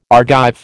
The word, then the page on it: Argive: American English